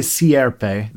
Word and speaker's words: Sierpe: By the way, I'm a Peruvian fan, and hearing you talk about Nazca and Monte Serpe, did I say that right? – Monte Sierpe, the sort Sierpe. Of, in Spanish, the vowels don't blend like that, like they do in English, so Sierpe.